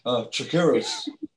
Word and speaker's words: Chakiris: Chakiris.